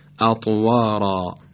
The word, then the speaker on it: اطوارا: یا مثلا فرض بفرمایید کلمه اطوارا، اطوارا بعد از حرف ر الف قرار گرفته و باید تفخیم بشه به این ترتیب اطوارا، اطوارا.